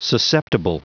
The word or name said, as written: susceptible